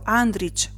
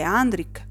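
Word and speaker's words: Andrich: Fa sorridere il fatto che i telecronisti pronunciassero Andric con la C dolce, quando invece per noi è Andric con la C dura, come la nostra frazione, che è anche un cognome diffuso in paese.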